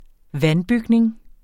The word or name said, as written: vandbygning